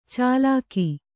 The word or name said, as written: ಚಾಲಾಕಿ